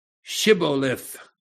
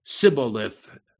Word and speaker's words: Shibboleth: Then they would say Shibboleth. Say Shibboleth. But he would say Sibboleth.